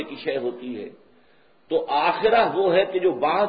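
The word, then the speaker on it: آخرة: عاجلہ آخرہ کے مقابلے میں، آخرہ آخرت جسے ہم آخرت کہتے ہیں، عربی میں اس کا تلفظ آخرہ ہوگا، اس آخرہ کے مقابلے میں دو الفاظ آتے ہیں.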